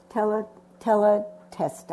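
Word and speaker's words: tetelestai: Telestai.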